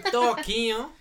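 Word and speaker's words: Toquinho: Toquiño.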